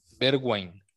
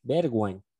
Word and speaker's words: Bergwijn: Bergwijn. Bergwijn.